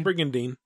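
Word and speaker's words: Brigandine: Green Dream says, well, since Brigadine is getting a sequel... Am I saying that right? – Yeah, Brigadine.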